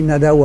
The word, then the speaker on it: Nadau: et puis Nadao après, parce que les gens disaient l'os, l'os, parce que ça s'écrit en, écriture, euh, en écriture classique euh, béarnaise, on écrit O qui se prononce OU,